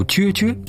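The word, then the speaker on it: 啫啫: 哎，注意啊，虽然在普通话里念折折，但在粤语里要读撅撅。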